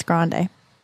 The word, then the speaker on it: Grande: I thought it was Grande. – I think it's Grande. Okay. – Grande? – Like Starbucks. – All right. – Like the word or the word Grande. Large from Italy?